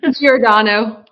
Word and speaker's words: Giordano: Giordano.